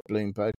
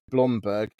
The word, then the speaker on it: Blomberg: um, and Beko is uh, a, a, you know is part of a stable of brands including Grundig, Blomberg, not Bloomberg, Blomberg, and Singer.